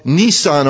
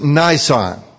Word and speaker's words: Nisan: Now it would be called Nisan, or some pronounce it Nisan.